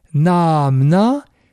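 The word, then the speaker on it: Naamna (नाम्ना): Naamna, avec deux syllabes longues, naamna est l'instrumental du mot neutre naman, le nom.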